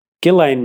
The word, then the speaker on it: Ghislaine: But Ghislaine Maxwell is how it's being pronounced by everyone on TV. – All right, fine. – Without the S.